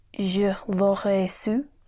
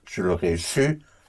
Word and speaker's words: Je l'aurais su: Je l'aurais su. – That was good. Je l'aurais su was well pronounced.